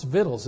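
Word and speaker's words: victuals: That is pronounced vittles, isn't it? I've looked that up and I can never remember that.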